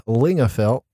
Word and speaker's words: Lingafelt: Uh, he is on social media, Chad Lingafelt, with that silent F.